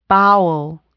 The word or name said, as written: bowel